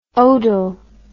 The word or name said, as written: odal